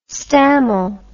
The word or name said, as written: stammel